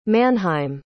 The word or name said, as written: mannheim